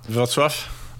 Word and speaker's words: Wrocław: Wrocław.